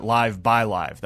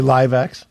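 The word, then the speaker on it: LiveXLive: And, and by the way, for our listeners and for you as well, that was with our partners at Live by Live. That's how you pronounce it when you say it out loud. – I can't say LiveX? – No, like South by Southwest.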